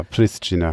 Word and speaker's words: Pristina: Ja, Pristina, von mir aus so.